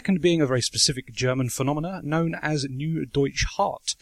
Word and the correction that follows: Neue Deutsche Härte: I don't know if that's how it's pronounced, but... – Neue Deutsche Härte. – Okay, there we go.